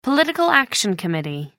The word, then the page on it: political action committee: British English